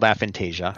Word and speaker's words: aphantasia: I think it's called aphantasia.